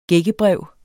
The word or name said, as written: gækkebrev